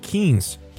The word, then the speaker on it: quince: She rolled her eyes and sighed, "It's just a queen's party. This is homecoming, and the crown's at stake." I say, "It's pronounced quince. Quinceañera," I said, very irritated.